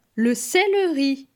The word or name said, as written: Le celeri